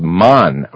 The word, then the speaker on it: Mon: The Germans spell it with an H, T-H-U-L-E, or the Thulas. And those were the Tibetan monks. They come from called Mon, or M-O-N, Mon.